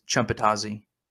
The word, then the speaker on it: Chumpatazi: Guys, that was not a spell that Rob just said. That was my last name. My last name is Chumpatazi.